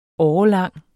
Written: årelang

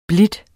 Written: blide

